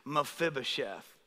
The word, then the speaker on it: Mephibosheth: His name is Mephibosheth. Can you say that with me? Mephibosheth. You guys killed it, man. You're smart, man. Got a bunch of smart people up here. I like it. Mephibosheth.